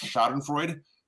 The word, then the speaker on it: Schadenfreude: Speaking of stats, every Seahawks fan in the Northwest is going through a healthy amount of, I believe it's pronounced Schadenfreude, enjoying the struggles of Russell Wilson and the Denver Broncos.